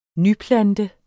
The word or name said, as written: nyplante